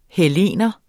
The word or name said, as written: hellener